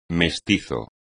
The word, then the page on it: mestizo: Spanish